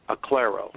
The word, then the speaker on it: Aklero: but that's spelled A-K-L-E-R-O, Acclaro.